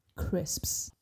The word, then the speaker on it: crisps: But it also has a consonant cluster at the beginning, the k, r, cr, as well. – So the correct pronunciation of that word will be crisps.